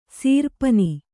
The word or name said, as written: ಸೀರ್ಪನಿ